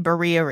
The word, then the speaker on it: birria: So you did birria ribs.